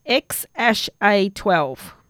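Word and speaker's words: X Æ A-12: The second character is Ash. It's pronounced X-Ash-A-12.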